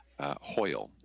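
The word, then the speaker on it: Hoel: Hoyle is how you pronounce his name,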